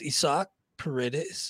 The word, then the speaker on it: Paredes: Indeed, it is pronounced Isak Paredes and picked him up for two.